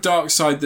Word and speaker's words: Darkseid: Is it pronounced Darkseid? – Yeah.